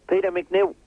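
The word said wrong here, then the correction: Peter McNeeley: Peter McNeely. Peter McNeely. – We don't have the time. – S-A-F-M.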